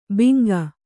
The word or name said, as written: ಬಿಂಕ